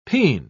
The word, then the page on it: pean: British English